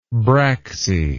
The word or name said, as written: braxy